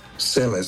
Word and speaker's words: Sele: But it's only S-E-L-E, Selle. So you pronounce it very good.